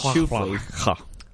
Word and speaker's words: Schuffele: S-C-H-L-A-F-L-Y. – Like my shoe has fleas. – No, it's more like shoe. – It kind of looks like shuck-la-fly. – Shuck-la-fly. – Shuck-la-fly. But it's Schuffele. Yeah, I looked it up. I looked up how to pronounce.